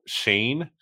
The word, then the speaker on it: Schoen: The name thing is throwing me off because apparently it's Joe Shane, but okay, there's an O-E. In his last it's spelled name. S-C-H-O-E-N. That's how it's spelled.